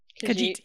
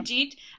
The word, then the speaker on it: Khajiit: How do you say the Elder Scrolls race? The Khajiit? – Khajiit, yeah. – Khajiit.